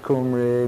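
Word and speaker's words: Cymry: But like I say, the Welsh had pronounced it Cumri, we had Cumbri, Cumberland, Kimbroi.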